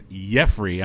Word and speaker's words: Yeffrey: That's correct. Yeffrey. I'm not trying to pronounce it J with a yay sound. – It's a Y.